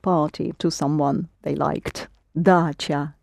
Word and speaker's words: dacha: Dacha. – Not quite. – Dacha? – Ч, yes, the dacha. – Dacha. It's the word dacha. And you do spell it in Russian with the letter ch.